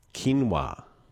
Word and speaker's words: quinoa: It's actually pronounced quinoa. Quinoa, not quinoa. Quinoa.